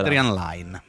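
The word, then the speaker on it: Adrian Lyne: Si intitola Proposta Indecente di Adrian Lane, Line. Come si pronuncia? – Adrian Lane. – Line, questo lo dici tu? – Lo dico io.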